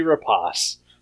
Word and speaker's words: Rapace: We are here to talk about Noomi Rapace.